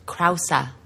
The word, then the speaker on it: Krause: Do you say his name Krause or Krause? – Krause. – Peter Krause, yeah. – Peter Krause.